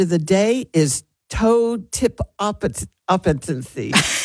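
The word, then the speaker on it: totipotency: It is toe tip appetency. It is spelled T-O-T-I-P-O-T-E-N.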